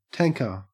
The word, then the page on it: tanker: Australian English